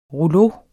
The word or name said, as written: ROULEAUER